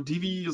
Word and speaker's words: Divi: Die Alena schreibt jetzt gerade ähm, parallel dazu, ich nutze Divi oder D-I-V-I, wie es richtig ausgesprochen wird.